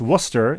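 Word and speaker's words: Wooster: Joanne writes, this is Joanne, parentheses, small a, no e, from Worcester, Ohio, pronounced Worcester. It does not rhyme with rooster, home of the College of Worcester, well known for their Division III men's basketball and baseball teams, amongst other things.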